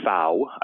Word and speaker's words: Pfau: Dr. Fau, I believe is how you pronounce it, P-F-A-U, has a lot of stuff online, a lot of fascinating academic papers.